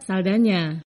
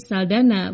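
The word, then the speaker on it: Saldaña: Dalam bahasa Spanyol yang umum di Amerika Latin, huruf N dengan tanda berliku diucapkan seperti Ny. Karena itu Saldana dengan huruf N yang disertai tanda berliku seharusnya dibaca Saldana. Sayangnya Hollywood lebih mengenal artis ini sebagai Saldana, bukan Saldana.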